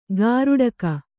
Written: ಗಾರುಡಕ

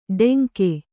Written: ಟೆಂಕೆ

